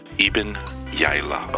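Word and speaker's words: Iben Hjejle: But then we have Ibn Gili. – Hey, Trailer Mark here again. And it turns out that the correct pronunciation is Ibn Yaila.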